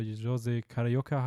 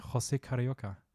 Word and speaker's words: José Carioca: Und die vierte Aquarella do Brasil, wo Donald und der bunte andere Vogel, der Jose Carioca heißt, Samba tanzen lernen. – Hey, das war ja richtig gut ausgesprochen. Ich Sag's nochmal. – Ähm, José Carioca.